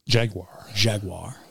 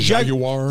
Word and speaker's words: Jaguar: Jaguar. – You ever hear the British pronounce it? Because it's a Jaguar. – That's a British company, isn't it? – It is. – Jaguar.